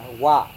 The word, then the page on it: wa: Serewen